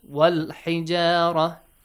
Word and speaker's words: والحجارة: Hamzatul wasl will be skipped. And ha is from the moon letters. Lam is pronounced. والحجارة.